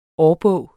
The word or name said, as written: årbog